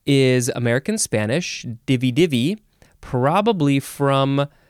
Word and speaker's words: divi-divi: The first word in this episode is, you can pronounce it divi-divi, the third syllable is emphasized, or divi-divi. Divi-divi, divi-divi. It is just D-I-V-I hyphen D-I-V-I.